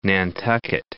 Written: Nantucket

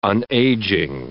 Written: unageing

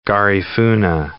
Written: Garifuna

